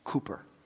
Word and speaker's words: Cowper: Now, it's spelled Cowper, but it's pronounced Cooper.